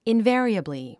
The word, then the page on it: invariably: invé ə riəbli; インヴァリエブリィ